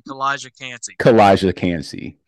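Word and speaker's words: Calijah Kancey: Elijah Canty. – Thank you. Him. – Who is it? – Oh, I gotta say it again. Kalijah Kancey.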